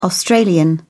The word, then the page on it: australian: British English